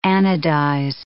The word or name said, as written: anodize